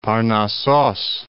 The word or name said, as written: Parnassos